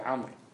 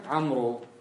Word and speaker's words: عمرو: عمرو، ما في شيء اسمه عمرو، ها عمرو، الواو فقط لتميز عمرو عن عمر، لا لتلفظ، والالف هنا فقط حتى يميز ايش؟